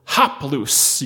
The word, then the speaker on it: haplous: It's the Greek word haplous. You can spell it H-A-P-L-O-U-S, haplous.